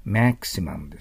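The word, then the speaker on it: maximum: だからその反対がマックスって日本語で言いますけど、英語だったらマキシマムです。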